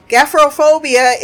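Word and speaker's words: gephyrophobia: What what is the P doing with the H doing with the Y doing with the R? I'll spell it for you. G E P H Y R O phobia. Gafrophobia is a fear of bridges.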